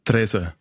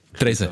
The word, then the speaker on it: Triesen: Äh, wie sagt man? Tresen. Entschuldigung. Das kann man nicht lernen. Wir, wir werden noch ganz viel falsch machen heute und falsch aussprechen. Tresen.